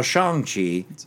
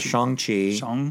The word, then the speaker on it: Shang-Chi: So Shang-Chi is how you say his name. So Shang-Chi.